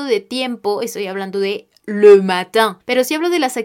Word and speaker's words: le matin: En este caso yo estoy haciendo referencia al periodo de tiempo que es le matin, le matin. Ahora, esta palabra se escribe el E por el artículo, espacio M-A-T-I-N.